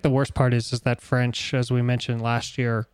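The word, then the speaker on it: Ffrench: he has that extra F in his name. So it's F-F-R-E-N-C-H, which is for just French. For French. So I wish people would pronounce it that way.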